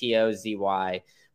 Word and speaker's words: Atozy: He's suing Atzy. I don't know if I said that correctly. It's Atozy.